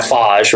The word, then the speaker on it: Foz: It's a F-A-Z is how you pronounce it. They call it Foz, right? – Okay.